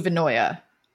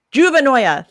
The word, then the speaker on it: juvenoia: Like paranoia, but with juvenile in the beginning. – Juvenoia. – Juvenoia, thank you, which is this concept.